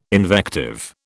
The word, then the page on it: invective: British English